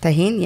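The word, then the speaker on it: Tajín: Tajin, yeah.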